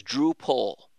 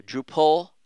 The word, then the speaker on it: Drupal: He said, "Hey, the people who work there pronounce Drupal as Drupal." All right. I don't know why I call it Drupal. I just do. Drupal rhymes with apple.